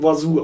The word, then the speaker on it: oiseau: Das heißt, das wird irgendwie o i s e u e x Ich weiß nicht, wie es geschrieben wird. Ich hatte nie Französisch. Irgendwas mit Oi. Es wird Oiseau ausgesprochen.